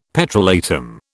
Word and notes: petrolatum: British English

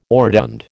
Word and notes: orotund: American English

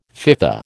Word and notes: FIFA: American English

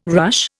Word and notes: ruche: Indian

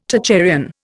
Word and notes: Tocharian: Indian

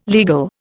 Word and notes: legal: Indian

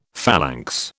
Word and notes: phalanx: British English

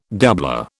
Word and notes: doublure: British English

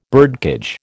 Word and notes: birdcage: American English

